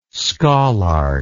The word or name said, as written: scholarch